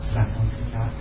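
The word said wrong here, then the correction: Svatantrika: Usually it is said like this. Swatantrika Madhyamika philosophy, Swatantrika Madhyamika philosophy uses logic, you know, like a really logic way.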